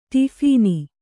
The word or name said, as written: ಟೋಫನ್